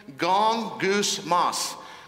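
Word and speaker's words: Gong, goose, moss (γογγυσμός): It has three syllables. Are you ready to hear this one? Gong, goose, moss. Gong like the gong show, goose like a bird, and moss like it grows on a tree.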